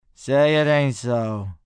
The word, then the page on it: Say It Ain't So: male